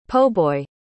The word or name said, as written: po'boy